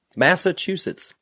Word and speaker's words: Massachusetts: How do you say Massachusetts.